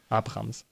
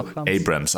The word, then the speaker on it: Abrams: Abrams. Wie spricht man das aus? Abrams. – Also Abrams eigentlich.